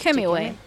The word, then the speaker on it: Hemingway: pero que Ernest Hemingway, se pronuncia así, ¿no? Sí, Hemingway.